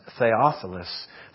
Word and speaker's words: Theophilus: With the most difficult name to pronounce, he's the most excellent, Theophilus.